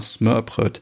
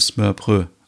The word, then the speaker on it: Smørbrød: Und zwar Smørbrød. Äh, jetzt habe ich das D ausgesprochen, soll ja Smørbrød eigentlich heißen.